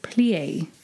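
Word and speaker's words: plié: Painting in P-L-I-E. Plie.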